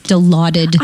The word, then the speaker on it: Dilaudid: People always act like they don't know how to pronounce Dilaudid when they ask for it really regularly. We know, mm-hmm. we know what you're up to. Okay, it's Dilaudid.